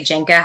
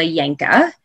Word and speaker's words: Yenka: And I never, like I said, it's not something that bothers me if people say Jenka. How could you just assume that that would be pronounced with as a Y?